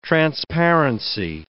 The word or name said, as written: transparency